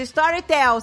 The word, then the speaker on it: Storytel: Gente, Storytel se escreve S-T-O-R-Y Storytel. Tipo telefone, tel.